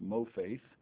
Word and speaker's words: mopheth: Exodus 7, now we're looking at mofaith, M-O-P-H-E-T-H, pronounced mofaith, Exodus chapter 7 and verse 8.